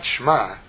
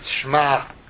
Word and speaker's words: Shema: Shema is not Shema. It's not an ayin. It's not a silent letter. It's pronounced. It's Shema. You didn't hear that? Shema. Some kind of choking sound, which Jews from the Middle East know how to do. We make an issue of pronouncing the dalet at the end.